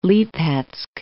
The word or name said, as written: Lipetsk